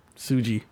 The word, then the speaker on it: Tsuji: Daisuke Tsuji. – Tsuji.